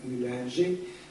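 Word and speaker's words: Uehlinger: L'imperialismo è quella interpretazione, come ho detto, è stata ripresa da Emanuele Tessa, poi da uno esegeta svizzero, Christoph Hüllinger, o Hüllinger, se preferite la pronuncia francese,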